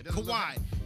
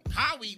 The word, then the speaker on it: Kawhi: But anyway, Kawhi. He said Cowie. What the fuck? – It doesn't look like how it's pronounced.